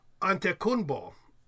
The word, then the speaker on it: Antetokounmpo: He pronounced it more like Antetokounmpo, without the middle sounds.